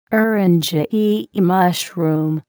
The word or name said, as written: eryngii mushroom